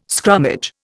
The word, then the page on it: scrummage: Indian